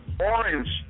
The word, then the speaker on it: Oran: And everybody keeps getting his name wrong. I didn't realize that until today. But we've always called him Orange Juice Jones. – Right, right, yeah. Oh yeah. – His name was Oran.